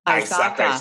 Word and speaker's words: ISACA: Is it, do I say ISACA? – Or it's ISACA. ISACA is how we pronounce it.